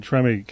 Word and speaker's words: Treme: I mean, he's in, how do you say it, Treme? – Treme.